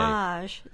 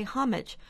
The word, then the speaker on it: homage: They say homage, as the French do. We say homage, which is perfectly reasonable since homage has been in the English language since 1300s, with its first syllable stressed. The homage is only an emulation of the French pronunciation.